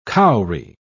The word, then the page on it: cowry: British English